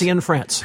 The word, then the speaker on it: Cianfrance: Cianfrance? – Cianfrance.